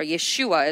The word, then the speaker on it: Yeshua: Heir means that God has planned to inherit all things to the Son of God, Jesus, or Yeshua, as we call him here in his original pronunciation.